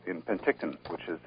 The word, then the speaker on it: Penticton: And I wasn't sure how to pronounce it. I'm glad you said Penticton. – Yeah. Penticton.